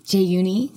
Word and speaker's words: Jaeyoony: Jay Yooni, because in Korea, people, when they say your name, they always add an extra syllable. So I was always Jay Yooni.